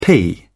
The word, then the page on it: pee: British English